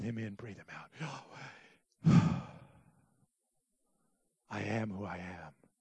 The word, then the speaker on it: Yahweh: But some rabbis have said the best way to pronounce it is just by breathing. Kind of sounds like Yahweh.